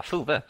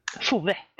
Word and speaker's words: Fove: Men jag vill så fruktansvärt gärna att Fove kommer igenom, eller Fove, eller hur man nu vill uttala det. Fove. – På svenska, Fove. – Fove, precis. Nu heter de Fove.